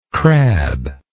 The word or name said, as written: crabbe